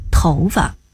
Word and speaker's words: 头发: 所有在表示毛发的时候都读作发，比如长发、短发、剪发、染发、黑发、白发，唯独跟头搭在一起时读轻声，头发。